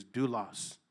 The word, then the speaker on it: doulos: It's called, the way you pronounce that in the Greek is doulos.